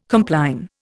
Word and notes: compline: Indian

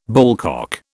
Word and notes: ballcock: British English

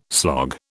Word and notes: slog: British English

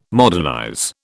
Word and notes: modernize: British English

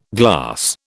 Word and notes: glass: British English

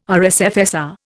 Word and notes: RSFSR: Indian

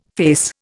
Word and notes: face: Indian

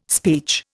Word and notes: speech: Indian

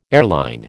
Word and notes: airline: American English

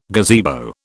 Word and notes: gazebo: British English

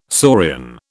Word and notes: saurian: British English

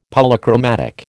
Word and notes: Polychromatic: American English

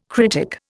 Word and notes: critic: Indian

